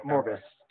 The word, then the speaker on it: Morbius: Is it Morbius? Or how do you pronounce that? – Morbius.